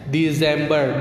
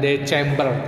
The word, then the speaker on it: December: Desember ya ini bacanya Desember bukan December tapi Desember ya